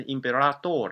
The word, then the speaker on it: imperator: 呃，这个词其实如果拉丁文应该念成 imperador 的，